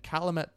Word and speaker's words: Calumet: So a father and son saw a huge bat creature fly over them in Calumet Park on May 5th of this year. – Mm-hmm. – Calumet, I think is how it's pronounced. C-A-L-U-M-E-T. Calumet. – Calumet. – Calumet. – Cal, you met? – Calumet Park, which will come up again later.